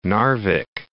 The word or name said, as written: Narvik